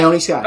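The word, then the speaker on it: Ione Skye: Is it Ione Sky? – Ione Sky.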